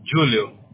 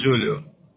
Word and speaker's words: Julio: Oh, yeah, because in Spanish it's Julio, but we are from Brazil, it's Julio.